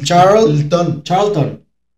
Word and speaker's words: Charlton: A ver, ¿cuál fue el último mundial que disputó que en pants descanse Sir Bobby Charlton? – Charlton. Al revés, Charlton. – Por eso. Bobby Pulina. – No es Tlon, es Ton. – Charlton. – Como Elton.